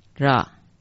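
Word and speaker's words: 啰: 第四个字口字旁一个罗，这个字念成 Ra，罗马拼音是写成 RA 这样的音，国语注音注成的啊啦，这个字很常出现，念成 ra 了。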